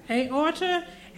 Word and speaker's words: aorta: it pushed up into his udder. How do you say that? – Aorta.